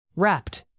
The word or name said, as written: wrapped